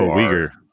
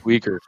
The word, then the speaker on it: Uighur: U-I-G-H-U-R. – Uyghur. Yeah. – Uyghur.